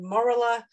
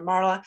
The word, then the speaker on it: morula: So, you know, day 4, you might have heard the word morula or morula, or people pronounce it lots of different ways. I always call it a morula.